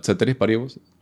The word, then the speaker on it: ceteris paribus: Eh, el contra, como decía, es que uno, pues, tienda ceteris paribus. – lo dije bien? Sí. – Buena pronunciación del latín.